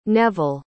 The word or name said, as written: Neville